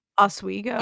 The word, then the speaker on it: Oswego: Like almost on the way to, I always pronounce the city wrong. Oswego? O-S-W-E-G-O? – Oswego. – Oswego, that one. Thank you.